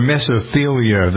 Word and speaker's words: mesothelioma: It's really pretty amazing how there's an ad for mesothelioma, that's how you pronounce it, which is what happens to you when you have been exposed to asbestos.